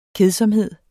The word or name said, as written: kedsomhed